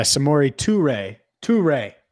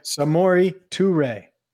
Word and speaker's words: Samori Touré: Yeah, Samori Touré. – What did I say? – Samori Touré. I don't know, but not that. Okay. But that's how you say it.